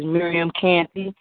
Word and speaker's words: Miriam Canty: It is Miriam Canty, and that's M-I-R-I-A-M. Canty, C-A-N-T-Y.